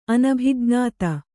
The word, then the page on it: ಅನಭಿಜ್ಞತೆ: anabhijnate